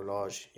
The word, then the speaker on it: Lage: How do you say his name? Bruno Lage, yeah.